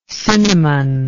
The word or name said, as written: cinnamonic